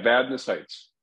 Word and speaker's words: Vadnais Heights: Dr. Dane Hansen, H-A-N-S-E-N. – You got it. – With Summit Orthopedics. He's in, I don't know how to pronounce this, Vadnais Heights? – Vadnais Heights. – Vadnais Heights and Woodbury.